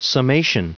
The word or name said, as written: summation